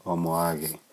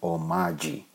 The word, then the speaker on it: Omoage: My American brethren, my brothers, my friends, they call me Franklin Omaji. And that's okay, as long as you know how to pronounce it. If you don't know it, fine, it's still okay. Omaji is fine.